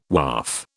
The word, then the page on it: Waaf: British English